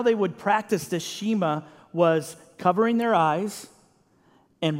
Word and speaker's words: Shema: Church, what Jesus just told them is what they would call, and what till today Jewish people will call, the Shema. Now, some of you may have never heard that word before. Some of you may have. It's spelled S-H-E-M-A.